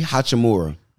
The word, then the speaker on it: Hachimura: Rui Hachimura.